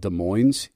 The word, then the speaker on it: Des Moines: a city called Des Moines. And yes, you do pronounce the last S.